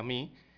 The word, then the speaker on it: Mu: Según precisa también esta entidad, la variante MI, eh, acá en Panamá le decimos MU, M1, Pero se pronuncia MI,